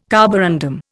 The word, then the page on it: carborundum: Indian